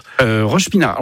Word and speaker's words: Roche-Pinard: Vous à Roche-Pinard. Alors, c'est ça, c'est bien comme ça qu'on prononce. – Roche-Pinard.